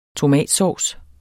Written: tomatsovs

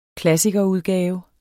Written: klassikerudgave

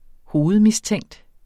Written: hovedmistænkt

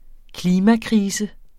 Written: klimakrise

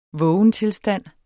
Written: vågentilstand

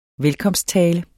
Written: velkomsttale

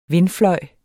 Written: vindfløj

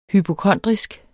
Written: HYPOKONDRISK

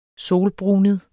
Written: solbrunet

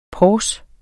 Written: PORSER

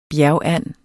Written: bjergand

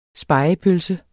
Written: spegepølse

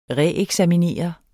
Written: REEKSAMINERE

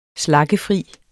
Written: slaggefri

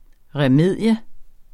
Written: remedium

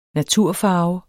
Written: naturfarve

